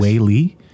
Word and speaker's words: Whaley: Is it Whaley? W-H-A-L-E-Y? – Yes. – Whaley? – Yeah. Yeah, Whaley. – So my reading's not that terrible. – You pronounced it right.